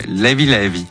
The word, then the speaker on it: Levé Levé: DJ Tom B est notre invité pour la sortie du volume 2 de ses compilations sur Sao Tomé et Principe qui s'appelle, moi je dis Levé Levé et ça se prononce pas comme ça. Comment ils disent là-bas? – Levé Levé. – Levé Levé. Très bien.